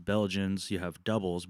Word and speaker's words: Dubbel: Well, in Belgians, you have doubles, but it's spelled like D-U-B-B-E-L. – I was wondering what that was. – Okay, so that's like a double.